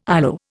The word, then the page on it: ILO: Indian